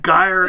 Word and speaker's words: gyre: Pronounced slithy as if the two words slithy make the G hard in gyre and gimble.